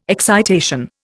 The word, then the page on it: excitation: Indian